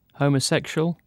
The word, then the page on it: homosexual: British English